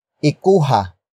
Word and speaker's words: ikuha: Word for word it's ikuha mo ng tubig ang aso.